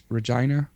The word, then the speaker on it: Regina: I personally always said Regina because in French you say Regina. But when I heard that in English it's Regina. – Regina.